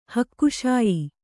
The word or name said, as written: ಹಕ್ಕುಶಾಯಿ